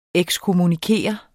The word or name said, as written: ekskommunikere